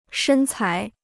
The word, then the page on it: 身材: shēn cái